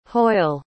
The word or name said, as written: hoyle